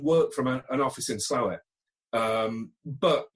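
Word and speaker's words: Slaithwaite: Well, I'm in Slawit, which I got told off horribly for when I called it Slaithway. So it's Slawit.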